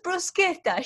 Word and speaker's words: bruschetta: I have met someone who's originally, as in like family, originally from Italy, and still pronounce bruschetta, and I look at them and say, you that always claim to be from Italy say bruschetta, it's bruschetta, shame on you.